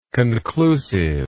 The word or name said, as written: conclusiveness